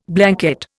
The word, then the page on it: Blanquette: Indian